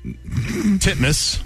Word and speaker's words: Titmus: Pronounce it the way it's spelled. – Ariane Titmus. Titmus. – There you go. – Is that how you say it? – Legitimately how you say it.